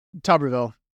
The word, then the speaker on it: Tuberville: Tuberville.